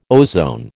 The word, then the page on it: ozone: American English